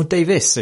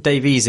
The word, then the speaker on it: Davies: I'm Marsh Davies. Or Davis, if you pronounce it correctly. But Davies, if you want to mispronounce it, but also have a clue how to spell it.